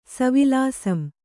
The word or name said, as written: ಸವಿಲಾಸಂ